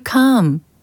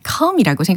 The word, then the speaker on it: calm: It makes you calm. 근데 좀 독특한 발음이었어요. 그래서 calm 이렇게 들렸는데 calm이라고 생각하시면 되는 거고 C-A-L-M이라는 철자입니다.